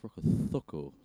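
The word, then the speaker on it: Rokocoko: Like, it's, I believe it's pronounced Rokotoko. Yeah. There's no bloody TH in there, you bastard.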